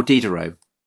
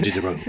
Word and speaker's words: Diderot: Or Diderot, you know, who kind of writes the encyclopedie.